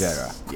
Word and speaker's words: injera: But the thing I always come back to that I'm fascinated by is the bread, the injera. Did I pronounce it right? – Yes, you did pronounce it right. It's yes. – Injera. Yes, yes.